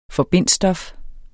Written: forbindsstof

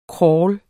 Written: crawl

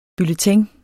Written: bulletin

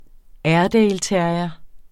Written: airedaleterrier

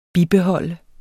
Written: bibeholde

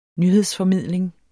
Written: nyhedsformidling

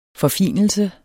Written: forfinelse

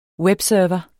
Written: webserver

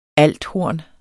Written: althorn

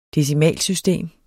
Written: decimalsystem

